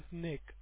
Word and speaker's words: Nick: Yeah, my name is Nick. That is spelled as N-I-C-K.